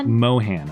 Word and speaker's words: Mohan: Immediate background check on Neil Mahon. Is that how you say it? Mohan, I believe.